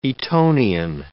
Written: Etonian

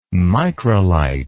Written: microlite

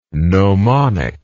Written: gnomonic

